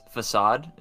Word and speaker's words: Facade: Vigoroth uses Facade. Am I saying that correctly? – Yes, you are. – Okay. I didn't know if it was actually the word Facade or something else. But yes, no, yeah, it's Facade.